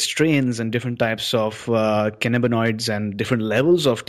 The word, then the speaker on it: cannabinoids: So three key things which I noted from what you said was there are over 100 different types of cannabinoids.